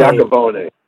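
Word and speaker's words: Yacovone: I can't pronounce his last name. Iacovone. Right.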